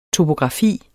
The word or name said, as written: TOPOGRAFI